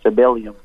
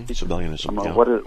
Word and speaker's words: Sabellianism: Sabellium. There you go. – Mm-hmm. Sabellianism.